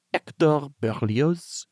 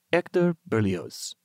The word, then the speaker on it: Hector Berlioz: And he said, in France, you would call him Hector Berlioz. But in English, of course, it is okay to say Hector Berlioz. – Oh, so still Hector. – He says, in France, we drop the H. We do never say the H.